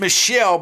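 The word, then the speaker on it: Mitchelle: Yeah, it's pronounced Michelle, but it's M-I-T-C-H-E-L-L-E.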